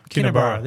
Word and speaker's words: Kinniburgh: Kinniburgh.